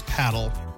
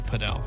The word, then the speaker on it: padel: We assumed it was paddle, but they were pronouncing it padel.